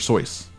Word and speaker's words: Seuss: It's Dr. Seuss. I'm gonna start leading the revolution to get his name right. Honor him. Honor his name. It's Dr. Seuss. Like, as in voice.